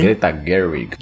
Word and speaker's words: Greta Gerwig: Barbie, esse filme dirigido pela Greta Gerwig, não sei pronunciar o nome dela muito bem. – Greta Gerwig.